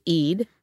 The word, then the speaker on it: Eade: And his colleague, Vice Chairman David Eade, which in a bunch of books and articles is rhymes with greed.